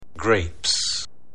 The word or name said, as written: grapes